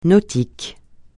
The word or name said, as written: nautique